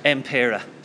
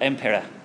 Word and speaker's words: Impera: Impera. – Now, we were in the pub last night. I have it written down, but my head is terrible. Say it. – Impera. – Spell it. – M-P-E-R-A.